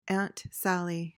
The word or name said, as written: aunt sally